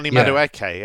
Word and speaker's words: Madueke: Is that how I say his name? – Madueke, yeah. Good player.